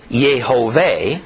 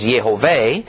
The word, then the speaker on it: Yehovah: Do we vocalize it like Yehoiada and Yehoshua, Joshua, and thus say the Lord's name is Yehovah, or do we vocalize it like in Yeshua or Yah and say Yehweh or Yahweh?